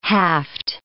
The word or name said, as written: haft